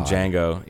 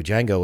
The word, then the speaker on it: Django: Django was such a good movie. God, I might have to go Django and not Inglourious Basterds. – Wow. The D is silent.